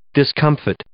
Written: discomfit

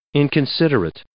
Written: inconsiderate

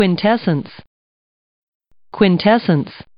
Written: quintessence

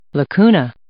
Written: lacuna

